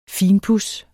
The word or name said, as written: finpuds